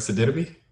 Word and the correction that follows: Sidibe: Gabby Sidibe. – That's how you say her name. Bro. Thought it was Sididibe.